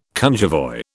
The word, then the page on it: cunjevoi: British English